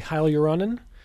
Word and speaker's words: hyaluronan: hyaluronan? Is that the right term? – Yes, absolutely.